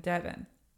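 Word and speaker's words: Deavan: So I want Devin to be spelled like Heaven.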